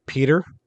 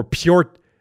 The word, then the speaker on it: Pyotr: Pronounce his first name for me because I can pronounce his last name. – Well, I've been going off of kind of like Peter. Like Peter. That's what I thought too. – Peter. Yeah. Kochetkov. – Or Pyotr Kochetkov.